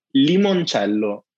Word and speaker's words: limoncello: You know, it's, now you can also learn it's pronounced limoncello. – Oh, yes. Okay. Lemon. Oh God. Don't let my Canadian tongue ruin this. – I know it's not easy, but it's not lemon. It's limon, like two E.